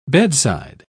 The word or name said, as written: bedside